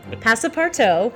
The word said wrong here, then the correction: Passepartout: which we did look up the pronunciation before the show. – Wait, so okay, playing... sorry. – Passa, passa. How's it spelled? It's it's Passepartout. – Passepartout. – Passepartout. Okay.